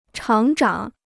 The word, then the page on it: 厂长: chǎng zhǎng